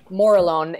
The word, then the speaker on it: Morillon: Morillon, which is the local name for Chardonnay. – So I've been. What? Say that again and spell it for me. – Morillon is a M O R I L L O N.